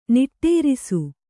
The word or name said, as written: ನಿಟ್ಟೇರಿಸು